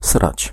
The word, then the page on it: schijten: /ˈsxɛi̯.tə(n)/